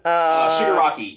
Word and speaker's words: Shigaraki: Shigaraki. – Yes, that one. Thank you. – Shigaraki.